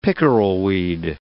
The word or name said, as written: pickerelweed